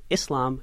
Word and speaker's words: Islam: Oh, so the proper pronunciation is a soft S for both Islam and Muslim. You know, there's almost a war on vernacular. So if you say Islam or Muslim, it has this very negative vibe to it. So the actual proper pronunciation is just Islam and Muslim, a very soft sound.